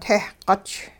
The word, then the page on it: tehquch: Hupa